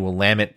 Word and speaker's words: Willamette: So the guys from Willamette University, I'm sorry you didn't win, but I am saying Willamette correctly now. So you guys have schooled me in the correct pronunciation, and I should have known that because I like wines from the Willamette Valley.